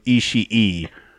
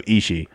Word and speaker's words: Ishii: Yeah, it's not Tomohiro Ishii. Just because there's two E or I's at the end of it, it's still Tomohiro Ishii. – It might be Ishii.